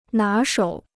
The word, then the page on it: 拿手: ná shǒu